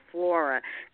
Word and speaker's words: Phlora: It's called probiotic, P-H-L-O-R-A, but it's pronounced flora, daily probiotic flora.